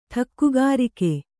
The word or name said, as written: ಠಕ್ಕುಗಾರಿಕೆ